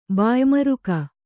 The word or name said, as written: ಬಾಯ್ಮರುಕ